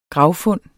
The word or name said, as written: gravfund